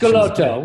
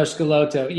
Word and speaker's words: Schelotto: Is it Schelotto? – yeah, well, we we go with all pronunciations. – Schelotto. Well, no, Schelotto.